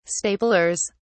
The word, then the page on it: staplers: British English